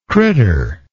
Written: critter

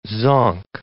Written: zonk

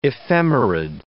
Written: ephemerid

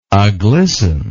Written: aglisten

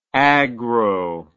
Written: agro

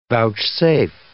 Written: vouchsafe

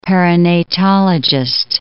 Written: perinatologist

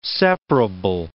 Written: separable

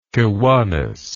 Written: Kiwanis

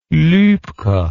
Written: Luebke

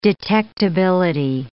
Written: detectability

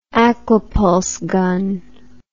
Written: aqua pulse gun

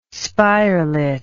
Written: spirelet

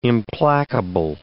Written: implacable

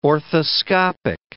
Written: orthoscopic

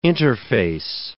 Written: interface

